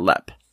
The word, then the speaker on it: Leap: Lepp Castle is spelled L-E-A-P, like leap, but it's pronounced Lepp.